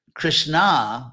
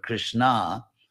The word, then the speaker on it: Krishna: And Krishna, with a long A at the end, which makes it a feminine word, Krishna, and therefore its Draupadi, was gained, was gained.